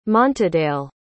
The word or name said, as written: montadale